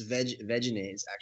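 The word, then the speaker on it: Vegenaise: Actually, it's Veganaise. – Oh yes, Veganaise. – Veganaise. – Yeah. it's Veganaise. Yeah, I've been saying it wrong.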